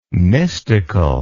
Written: mystical

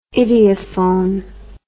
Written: idiophone

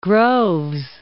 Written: Groves

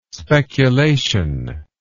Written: speculation

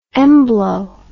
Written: embolo

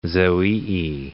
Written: zoeae

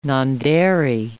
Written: nondairy

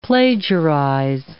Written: plagiarize

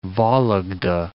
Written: Vologda

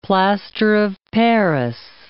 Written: plaster of paris

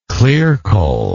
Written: clearcole

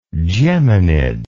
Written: geminids

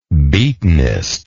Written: beatenest

